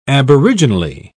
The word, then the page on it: aboriginally: British English